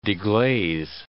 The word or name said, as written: deglaze